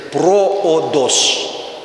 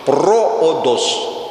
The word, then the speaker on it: проодос: По-гречески же, запишите, это проодос. Прямо по-русски пишите. Проодос. Проодос. Ударение на первое слово.